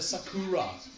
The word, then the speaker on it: Sakura: Men de i Japan ville si Sakura.